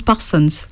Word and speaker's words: Parsons: Moi, je prononce Parsons. Les gens l'entendent Parsons. Les Américains prononcent différemment, mais moi je dis toujours Parsons.